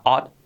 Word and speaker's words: ought to: Se escribe ochtu, ochtu, ochtu, ochtu, se pronuncia ochtu.